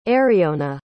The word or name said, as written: aeriona